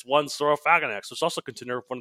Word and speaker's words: Saurophaganax: Saurophaganax. Well, it's like, it's the same terminology that sarcophagus comes from, so Saurophaganax.